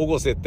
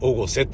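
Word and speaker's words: 越生: うのは心配だけど、そもそも、その、時川温泉の界隈が、あ、おごせ駅すっげえ風情ある駅だった。ああ、今度ちゃんと行ってみよう。おごせってあの、越境の越にね、超越の越に生きるね。で、おごせって読むんですよ。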